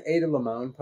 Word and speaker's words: Ada Limón: Can we read an Ada Limón poem? – No. – Do you notice how I said her name right that time? – Yes, well done.